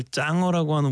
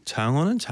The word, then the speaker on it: 장어: 펄디 발음 장어를 짱어라고 하는 우리 아빠 스타일인데, 장어는 장어라고 합니다. 네, 짱어라고 하진 않아요.